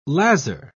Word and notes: lazar: British English

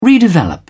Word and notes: redevelop: British English

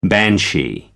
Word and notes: banshee: American English

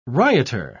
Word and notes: rioter: British English

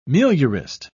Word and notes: meliorist: British English

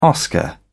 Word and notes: oscar: British English

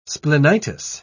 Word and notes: splenitis: British English

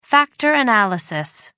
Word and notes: factor analysis: American English

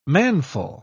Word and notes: manful: British English

